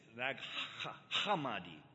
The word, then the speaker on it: Nag Hammadi: is the study of the Nag Hammadi. And I don't know if I said that right. It's Nag Hammadi. I got to get that, you know, that sound in there.